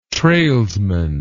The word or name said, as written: trailsman